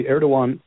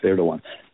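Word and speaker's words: Erdoğan: Actually, Erdogan, not that it's a big deal, but it's pronounced Erdogan.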